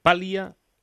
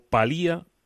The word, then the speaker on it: paliar: Una medida, mm, por ejemplo, que tome el, gobierno, palía eh, palia o palía la crisis sanitaria. cómo debemos pronunciarlo? – de las dos maneras eh, vale.